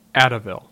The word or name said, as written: Addieville